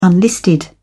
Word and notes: unlisted: British English

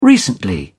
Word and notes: recently: British English

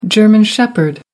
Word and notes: German shepherd: American English

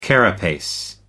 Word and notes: carapace: American English

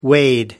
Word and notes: wade: American English

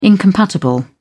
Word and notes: incompatible: British English

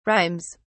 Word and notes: rhymes: British English

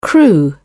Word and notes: crew: British English

